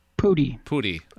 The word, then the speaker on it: Pudi: Danny Pudi, is that how you pronounce his last name? – Pudi.